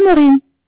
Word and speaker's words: Maureen: Alors, c'est Maureen. – Alors, ça s'écrit M-A-U-R-E-E-N.